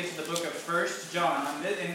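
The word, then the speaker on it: 1 John: And just so you know, so the, the name of the book is First John. That's how you say it, even though it says One John, we say First John.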